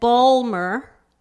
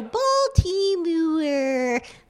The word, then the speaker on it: Baltimore: They didn't want, and I guess, I surmise, I judge, I that they that Balmer, that the local pronunciation sounded to them what they would have called too Gentile, maybe too Southern, maybe insufficiently elegant, but they were careful and scrupulous to pronounce every single syllable, consonant, vowel, and then to add some, right? So Baltimore, they, you know, there's a very, very, there are many vowels between the M and the R.